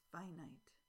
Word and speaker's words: finite: Sand is finite. Sand is finite. Notice that finite is pronounced much differently than infinite, infinite, finite.